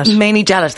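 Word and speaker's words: Mainie Jellett: and our very own Manet Gellet. I'm pronouncing that correctly, am I? – Manet Gellet, there you go, which depicts this amazing story.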